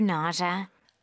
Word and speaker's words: nausea: 이거 주 발음을 좀 가볍게 했는데 네. That's fine. Nausea. 네. – 다 알아듣지. 그럴 때 그죠? Nausea. 이거를 nausea 이렇게만 안 하시면 되는 거지. 네. – 스펠링은 S-E-A가 맞는데 네. 발음은 주, 주. Nausea.